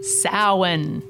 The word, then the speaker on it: Samhain: Samhain. Thanks for pronouncing it properly.